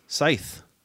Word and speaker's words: Scythe: From Stonemaier Games, Scythe. And yes, it's pronounced Scythe, not Scythe. – It's not Scythe, it's Scythe. – It's not Scythe.